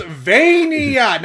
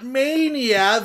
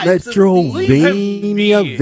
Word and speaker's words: Metroidvania: It's pronounced Vania, not Mania. Vania. – Metroidvania.